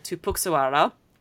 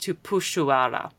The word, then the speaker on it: Tupuxuara: Unlike the family name that has an X in it, which in Portuguese, in Brazilian Portuguese, is pronounced like a sh. So the word for cup in Brazilian Portuguese is xícara, not xícara, if I was pronouncing it with my really broken Australian Queensland accent. But yeah, so my supervisor and I were saying the word tupuxuara, but it should be pronounced tupuxuara.